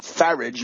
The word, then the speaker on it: Farage: That is the scurrilous Nigel Farage, rhymes with garbage, and the blonde pretty boy Boris Johnson, who is now on the inside track to become Prime Minister of Great Britain.